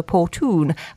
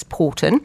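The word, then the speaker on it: Poretoun: Now, I did actually ring one of my Scots friends and ask how I should pronounce that, Porton Woods, because it's spelled P-O-R-E-T-O-U-N. I wasn't quite. I would have said from the south of the border, Portoon, but apparently that's not right. It's Porton.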